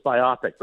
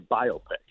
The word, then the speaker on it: biopic: So I used to think it was biopic, but it is actually biopic.